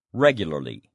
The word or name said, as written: regularly